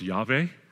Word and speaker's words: Yahweh: We put the tetragrammaton, Y-H-W-H. We don't know exactly how it's pronounced, but the closest and best guess is that it's pronounced Yahweh.